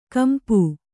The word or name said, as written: ಕಂಪ